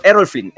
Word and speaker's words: Errol Flynn: Bueno, es una mansión en Los Ángeles que en su momento había comprado este eh, Erin eh, no estoy pronunciando, creo que lo estoy pronunciando mal, eh, un actor, Errol Flynn, Errol Flynn, un actor de Hollywood bastante famoso en su época, y Rick Rubin compra después esta mansión.